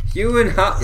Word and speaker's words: Gyllenhaal: Jake Gyllenhaal. – Gyllenhaal. – That's how you say it.